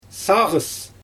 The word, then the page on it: saaGys: Xyzyl